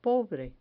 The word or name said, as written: pobre